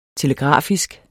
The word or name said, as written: telegrafisk